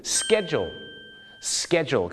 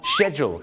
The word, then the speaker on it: schedule: schedule ครับแต่ปรากฏว่าชาวอังกฤษหลายคนมากนะครับก็จะออกเสียงว่า schedule ครับผมชอช้างนะฮะ schedule นะฮะคล้ายๆชอช้าง she นะฮะ she schedule นะ